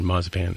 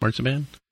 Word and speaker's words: marzipan: Very German pronunciation. Marzipan. Mm. Marzipan? – Marzipan? – Whatever. Marzipan. – What's the Canadian pronunciation? Marzipan? – I think it's marzipan. Just like the American. Marzipan. But my wife says marzipan, which is the German pronunciation.